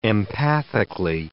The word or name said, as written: empathically